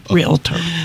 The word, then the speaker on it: realtor: No one has used the word realtor. They've all used the word realtor. – Oh, boy. – Two syllables. There we go.